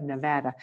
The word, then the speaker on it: Nevada: She is the executive director of Foster Kinship, a nonprofit organization devoted to the support of kinship families in the state of Nevada. Or actually, I said that wrong. I should say the state of Nevada. As a Southerner, I can't help but always mispronounce that.